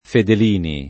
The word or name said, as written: fedelini